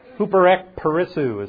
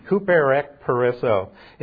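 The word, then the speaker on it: Huperek perissou (ὑπερεκπερισσοῦ): Huperek perissou is how it's pronounced in Greek. Huperek perissou.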